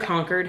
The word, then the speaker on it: Concord: Concord. How do you say it? – Concord. It's not like a Concord grape.